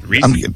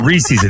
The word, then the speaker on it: Reese: Reese. Yeah. – It's not Reese's. It doesn't rhyme with feces, Nate.